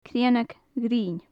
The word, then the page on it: cruinneag ghrinn: gaelic